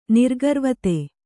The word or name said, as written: ನಿರ್ಗರ್ವತೆ